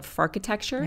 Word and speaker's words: farkitecture: You call it farkitecture. – Yes, that's right. – Am I saying that right? – That's right. Well done.